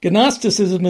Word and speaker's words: Gnosticism: And yes, I know that the G is supposed to be silent, but if they're going to insist on putting it in there, then I'm going to pronounce it. Anyway, Gnosticism is almost more Neoplatonism and mystery cult esotericism than it is Christian.